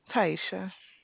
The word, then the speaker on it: Tyesha: Taisha.